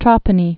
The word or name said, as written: Trapani